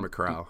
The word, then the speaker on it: Macau: Macau, is it Macau or Macau? – Macau.